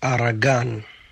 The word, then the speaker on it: Aragan: So it's actually pronounced Aragan. Aragan means sweat, like your sweat, you know, you're working hard and there's sweat on your face.